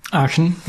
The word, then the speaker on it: Aachen: Did I say that right? Is it Aachen? Aachen, yeah.